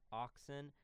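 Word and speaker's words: ØXN: And just because, in order to help, because you will never be able to spell these right on your own, that's O-X-N, pronounced Oxen, and then Sirum is C-Y-R-M.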